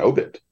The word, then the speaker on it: obit: So when you came to the word, you know, obit, it was like, oh, okay, it's an obit because that's the way it is in obituary. But in the newspaper game, it's an obit. It's the first syllable.